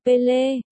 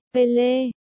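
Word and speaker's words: Pelé: Đầu tiên, nàng muốn được là một người tốt và cố gắng sửa phát âm của họ cho đúng. Là Pele, Pele, nhưng chỉ một lát sau, nàng từ bỏ ý định đó, vì họ cũng khăng khăng gọi nàng là Maria.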